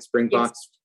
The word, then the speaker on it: Springboks: The story of Nelson Mandela, and we talked about this in the beginning, and the Springboks, did I pronounce that right? Springboks, yes. Rugby team, which was made into a movie with Matt Damon and Morgan Freeman, which was a seminal moment in your country's history.